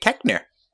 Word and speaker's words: Koechner: I believe it's pronounced David Koechner, as Jenna Koechner? Fisher on the Office Ladies podcast would say.